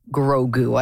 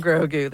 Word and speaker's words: Grogu: Grogu? I don't know if I'm saying that right. – Absolutely, you are. – Okay. – Like an old fan, just saying Grogu there.